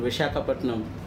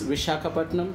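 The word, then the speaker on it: Visakhapatnam: In Vishakhapatnam. – That's it. Say it again. Say it again slowly. – It's Vishakhapatnam, right? – The spring city of India.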